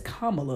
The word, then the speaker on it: Kamala: It's Kam- it, it, it's Kam- it's Kamala, like I'm saying right now.